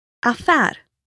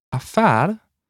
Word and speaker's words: affär: And finally, for words of French origin, the stress falls on the final syllable. – Affär. – Affär.